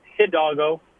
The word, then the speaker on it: Hidalgo: Is it H- Hidalgo? Is that how you pronounce the last name? Um, uh, yeah, Hidalgo.